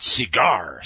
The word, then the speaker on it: CGIAR: Since 1979, though, under World Bank auspices, a consortium of agricultural research centers known as the Consultative Group on International Agricultural Research, I'm going to pronounce it CIGAR, C-G-I-A-R,